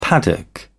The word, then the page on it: paddock: British English